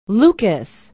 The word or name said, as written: lucas